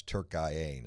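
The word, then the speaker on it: Türkiye: I've had a lot of people texting me, a very limited group though, upset about them referring to Turkey, who's been eliminated, as Turkiye now, which is the correct pronunciation that the Turks call their country, which is fine.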